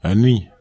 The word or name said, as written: eni